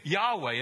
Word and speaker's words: Yahweh: In fact, the name became so sacred to the Hebrews that they wouldn't pronounce it, but use the consonants for the name, YHWH. And when the vowels are added in, for pronunciation's sake, we get Yahweh as God's name.